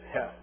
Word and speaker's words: heth: The next section, which is heth, or heh, that's the vowel that nobody likes to pronounce because it sounds like you're clearing your throat.